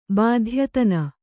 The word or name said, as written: ಬಾಧ್ಯತನ